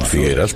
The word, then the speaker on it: Figueres: Figueres, puedes decir Figueras porque es una E abierta.